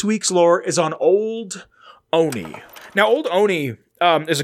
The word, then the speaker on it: Olney: But it's like spelled dumb, O-L-N-E-Y, but it's pronounced Oni, like the animator.